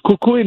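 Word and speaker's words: kukui: Am I pronouncing that correctly? – Yeah. Yes, it's kikuyu nut, which is a candle nut.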